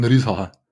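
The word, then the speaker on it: Risacher: Neben dem Sacré-Lüser, oder? – Absolut, sah gut aus in der Preseason. – Ja. Den muss man eigentlich bayerisch aussprechen, oder? Ein Riesacher.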